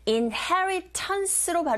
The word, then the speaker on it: inheritance: 그리고 inheritance로 발음하기도 하고 또는 t 사운드 자체를 자, 강모음과 양모음 사이에 자리 잡고 있어서 r 소리에 가깝게 들리게 발음하시면 돼요. indigenous inheritance 이렇게 해보세요.